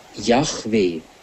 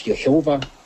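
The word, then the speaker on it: Jahwe: Der Name Gottes ist ja nach dem Alten Testament, wie er ihn beim brennenden Dornbusch Moses offenbart hat, IHWH, Yahweh. Es gibt da eine falsche Übersetzung, die dann auf Jehova kommt. Und das ist eine monströse Aussprache, heißt es im Lexikon für Theologie und Kirche.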